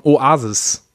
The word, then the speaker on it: Oasis: Kennst du die englische Rockformation Oasis? Bekannt von so Songs spricht man ja auch genauso aus.